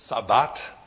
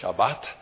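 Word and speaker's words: shabbat (שבת): The Hebrew word sabbat, or it's pronounced by Jews today as shabbat, is from a root word that means to stop, to cease, or to keep.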